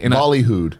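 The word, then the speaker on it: ballyhooed: The Epic Game Store's much balooied. Bahooied? How do you say it? Balooied? Balooied, right? It's a weird one. I've seen it written out, but I've never said it. – Bollyhooed. – Bollyhooed. Much balooied.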